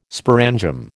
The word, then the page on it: sporangium: American English